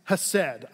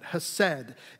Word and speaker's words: hesed: I'm gonna butcher the pronunciation of it because it's a very guttural word, and we don't want that stuff coming out, right? So it's hesed. I'll use the soft version of it, hesed.